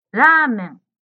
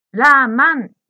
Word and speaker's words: ラーメン: ジムさんのラーメンがラーマンに聞こえました。そしてうどんがうでんに聞こえました。真ん中の文字の発音が少し難しいですね。